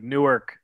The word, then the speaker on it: Newark: It's spelled like Newark. It's just I remember it always being like Newark, New Jersey, and then you go there and they're like, no, it's Newark here.